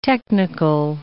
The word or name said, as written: technical